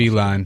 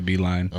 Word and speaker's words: Beilein: I don't know how to pronounce Beilein. – It. Beilein. That's how you pronounce it? – Yeah, Beilein.